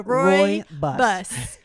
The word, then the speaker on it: rooibos: So forever ago, when I first started in the business, one of the people that introduced me to tea said it's Roy Rogers drives a bus. So I've never said it wrong because I always think of Roy Rogers drives a bus. So Roy bus. – So why don't you tell us what is rooibos, and there I go saying it wrong.